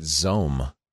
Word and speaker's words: XOHM: It's spelled WiMAX, but they pronounce it Zohm.